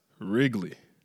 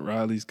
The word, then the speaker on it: Wrigley: But it's been brought to my attention that it's pronounced Wrigley. It's not Riley's gum. It's Wrigley gum.